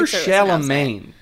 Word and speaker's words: Chalamet: It's for sure Chalamet, by the way.